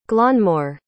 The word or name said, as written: glanmor